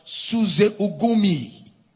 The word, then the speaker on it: suzeugnumi: The word join together there is the word suzeugnumi in the Greek. I can spell it for you. S U Z E S U Z E U G N U M I. Suze Ugunmi.